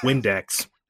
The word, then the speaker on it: Vindex: Vindex. – And no. let's remember that in classical Latin, V's are pronounced as W's, so we are in fact talking about Windex.